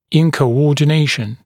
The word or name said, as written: incoordination